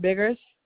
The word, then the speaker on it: Biggers: Deshaun Biggers.